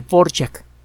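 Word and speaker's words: Dvořák: Borjak. Se escribe de Borak, D-V-O-R-A-K, Antonin Borjak.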